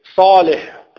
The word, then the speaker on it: صالح: لا قل صالح صا صا جيد هكذا صا صالح ليس صو صا افتح فمك وقل صالح صا هكذا نعم ما شاء الله.